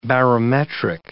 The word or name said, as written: barometric